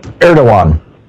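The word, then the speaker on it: Erdoğan: Erdogan is how it's pronounced.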